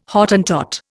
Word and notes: Hottentot: Indian

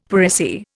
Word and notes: per se: Indian

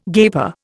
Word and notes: gaper: Indian

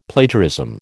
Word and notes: Plagiarism: American English

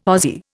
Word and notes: Aussie: Indian

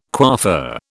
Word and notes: coiffeur: British English

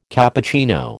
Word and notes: Cappuccino: American English

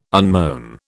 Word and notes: unmown: British English

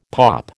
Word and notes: POP: American English

